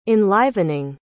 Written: enlivening

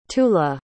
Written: tula

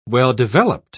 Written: well-developed

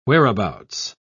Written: whereabouts

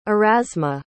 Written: erasma

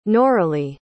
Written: noralie

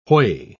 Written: whey

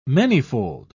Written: manyfold

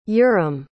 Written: yurem

